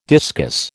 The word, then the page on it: discus: American English